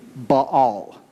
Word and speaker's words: Baal: Now, just so you know up front, the correct pronunciation of this god's name is Baal. Okay, but as I was going through this message and preparing it, it felt awkward to keep saying it that way, Baal.